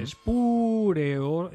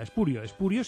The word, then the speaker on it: espurio: Espureo, esdrújula, con hiato, espureo, con pu es una sílaba, re es otra, io es otra, espureo, eso es incorrecto. Forma incorrecta la esdrújula. Espurio, sin acento, por lo tanto, significa falso bastardo.